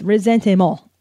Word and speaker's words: ressentiment: Okay, so today's topic is resentment, resentiment, as the French like to say, and I swear to God, that is how they say it.